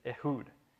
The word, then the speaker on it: Ehud: And I've also always grown up saying Ehud, but apparently that was wrong. I found out as I studied this in depth. The correct pronunciation is Ehud.